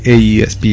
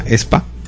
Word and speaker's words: aespa: El primero, el grupo no se llama AESPA, de hecho se llama ESPA. – ESPA, sí, o sea, se pronuncia, o sea, así, ESPA. – Se escribe AESPA y se pronuncia ESPA.